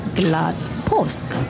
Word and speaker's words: glad påsk: So today's phrase is glad påsk. Glad påsk, which literally means happy Easter.